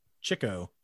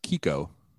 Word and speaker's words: Chicco: Chico. – Apparently, it's pronounced Kiko.